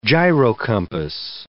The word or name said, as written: gyrocompass